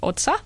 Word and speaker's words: Otsa: I've got a page which is under my name, which is Rahel Otsa. – You better spell that. – R-A-H-E-L-O-T-S-A.